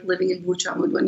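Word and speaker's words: Bourj Hammoud: And my family lived in a little town called Burçamud. Am I saying that right? – You said it very good. – Burçamud.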